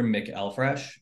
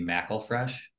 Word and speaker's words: McElfresh: Glenn Arthur McElfresh, but uh, depending on who you talk to at our Thanksgiving dinners, it may be McElfresh.